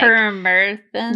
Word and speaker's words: permethrin: It's like permethrin.